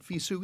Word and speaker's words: FISU: FISU is FISU. How they say it.